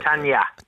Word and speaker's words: Tanja: T-A-N-J-A. Tanja. – Tanja.